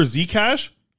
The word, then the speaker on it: Xhekaj: Sorry, uh, you know, and I'm excited to see this Wi-Fi guy, this Arber Arber Xhekaj. That's how you say it.